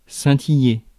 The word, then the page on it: scintiller: [sɛ̃.ti.je]; France